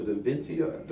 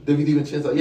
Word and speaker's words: DiVincenzo: The guy kid that went to Villanova, DiVincenzo. – DiVincenzo, yeah.